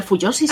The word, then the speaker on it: fujoshi: ¿Eres Fujoshi? – ¿Fujoshi? – Sí. – ¿Fujoshi? No lo sé, pero Fuyoshi sí. – Ah, eso, bueno, Fuyoshi. Perdona mi pronunciación.